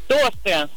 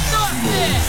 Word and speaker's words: тосты: Тосты. – Да! Да! Тосты! Ну тосты же! Так что ударение в данном слове всегда и независимо от его значения падает только на первый слог.